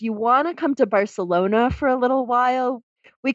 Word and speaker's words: Barcelona: And then Let's say it correct. Let's yes. say it correctly. Barcelona. – Barcelona. Gracias for the correct correction.